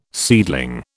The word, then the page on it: seedling: British English